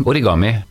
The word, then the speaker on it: origami: Du har jo òg holder på med noen ting som heter for origami. Er det riktig uttalt? – Ja, eh, origami.